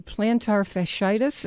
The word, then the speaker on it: plantar fasciitis: Well, to get back to the heel pain, plantar fasciitis, am I pronouncing that correctly? – Yes, you are.